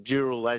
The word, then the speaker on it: Dural: D-O-O? – It's D-O-O-R-A-L, which is the Indigenous people from up there, the Darug people, and it meant burning log. So Dural. That's what Dural, as in D-U-R-A-L. And that's what apparently in the Indigenous language, it's Dural, as in D-O-O-R-A-L.